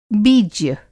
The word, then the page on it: बीज्य: Hindi